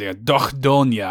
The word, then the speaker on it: Dordogne: We had the great honor to be passed along a review code for Dordogne, however Dordogne, you're supposed to pronounce it, and Dordogne, I think, is the actual for you got to put a little bit of that French phlegm in there, and you got it.